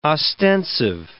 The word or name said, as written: ostensive